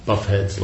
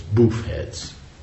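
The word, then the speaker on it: boofhead: That would be what do you call it? That would be the wrong pronunciation. I think the pronunciation is boof heads.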